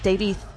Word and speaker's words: David: David was his name, the last syllable pronounced closer to a TH, Davith.